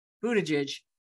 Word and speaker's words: Buttigieg: Pete Buttigieg pronunciation. Here we go. – Pete Buttigieg. – Buttigieg. – Pete Buttigieg. – Pete Buttigieg. Pete Buttigieg. – There we go.